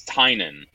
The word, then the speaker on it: Tynan: It's the classic Irish spelling would be more T Y N A N, and my family still pronounces it that way, but it hasn't been spelled that way in about 200 years.